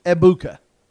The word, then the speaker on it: Ebuka: You don't pronounce the G. It's just Ebuka.